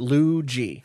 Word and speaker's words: Ludjy: Luigi, great name, L-U-D-J-Y, pronounced Luigi.